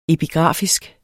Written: epigrafisk

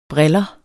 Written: briller

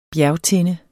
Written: bjergtinde

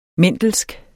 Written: mendelsk

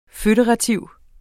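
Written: FØDERATIV

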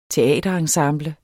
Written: teaterensemble

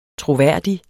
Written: troværdig

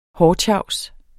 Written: hårtjavs

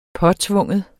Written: påtvinge

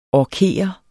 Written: orkere

